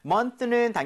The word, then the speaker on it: month: So, month는 당연히 개월을 얘기를 하고요. 발음할 때 months라고 하지 않는 것만 주의해 주세요. Okay? – 네. – So, month에서 윗니, 아랫니 사이에 혀가 살짝 끼는 거지만 이렇게 나오지 않는다고 했습니다.